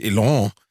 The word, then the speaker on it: Elon: Ich weiß übrigens, dass er Elon heißt, aber ich sag Ellen. Weil das ist, Alan? Ich kann ja nicht aussprechen. Elon, wer heißt denn Elon? Elon. Das ist Elon.